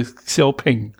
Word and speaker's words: Xiaopeng: Hersteller Xiaoping, wie man spricht. Äh, Xiaopeng.